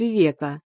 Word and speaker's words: Вивека: Меня зовут Вивека, с ударением на «е».